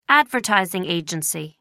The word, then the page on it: advertising agency: American English